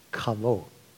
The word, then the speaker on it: Hobab: And it could, in Hebrew, it would be pronounced Kavo.